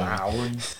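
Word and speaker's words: Lowrey: Sorry, that's just how they say it in the movie. – Lowry.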